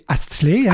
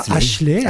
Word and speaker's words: Astley: Prononcé Astley, hein, Astley, pas Ashley, Astley, hein, c'est Astley. Astley. Pendant des années, j'ai dit Ashley, mais non, Astley, c'est Rick non, c'est Astley.